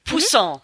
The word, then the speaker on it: Poussin: Poussin wird also, übrigens ausgesprochen. Jetzt habe ich es gerade gesehen. Poussin.